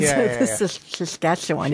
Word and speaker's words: Saskatchewan: They're probably in like Saskatchewan. – Right, exactly. Saskatchewan. You have to say it like that.